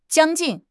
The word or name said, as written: 将近